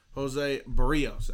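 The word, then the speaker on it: José Berríos: Jose Berrios, actually, is how you pronounce it.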